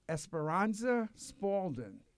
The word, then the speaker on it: Esperanza Spalding: Esperanza Spalding.